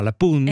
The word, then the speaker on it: La Punt: Et moi, j'ai appris comme parole, je ne savais pas qu'on dit, on ne dit pas le pont, on dit la, donc c'est féminin, pun, alors qu'il y a un T à la fin, mais on ne le prononce pas, la pun.